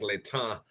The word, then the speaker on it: Letang: So growing up, I was corrected, and it's Alick Letang,